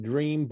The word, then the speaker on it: DREM: How do they spell that, Doc? D-E-M? – D-R-E-M. – D-R-E-M, yeah. They pronounce it Dream, but it's a blend of herbs that helps to relax the body.